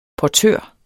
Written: portør

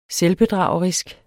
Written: selvbedragerisk

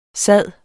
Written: sid

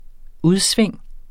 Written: udsving

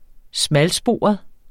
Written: smalsporet

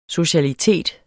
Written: socialitet

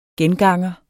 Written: genganger

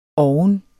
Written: oven

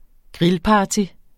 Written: grillparty

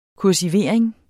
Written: kursivering

